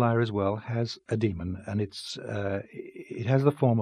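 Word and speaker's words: dæmon: Yeah, I pronounce it demon, but it is spelled D-A-E with a ligature, M-O-N.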